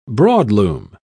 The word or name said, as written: broadloom